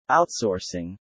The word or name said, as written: outsourcing